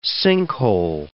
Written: sinkhole

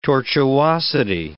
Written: tortuosity

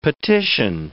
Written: petition

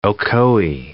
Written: Ocoee

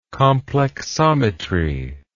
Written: complexometry

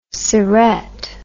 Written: syrette

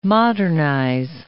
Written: modernize

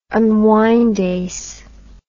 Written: unwindase

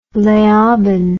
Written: leoben

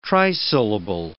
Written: trisyllable

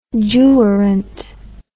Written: jurant

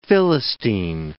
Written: Philistine

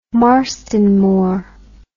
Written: marston moor

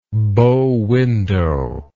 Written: bow window

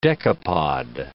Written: decapod

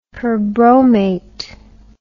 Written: perbromate

